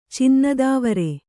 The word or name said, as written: ಚಿನ್ನದಾವರೆ